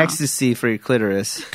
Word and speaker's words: Extase: but it's E-X-T-A-S-E, and it's pronounced ecstasy.